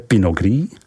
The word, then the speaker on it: Pinot Gris: Silvánské zelené, Pinot Blanc, vyslovujeme Pinot Blanc, Muscat d'Alsace, vyslovujeme Miska d'Alsace, Pinot Gris, vyslovujeme Pinot Gris, Riesling, tato odrůda je nejvíce v Alsasku rozšířena.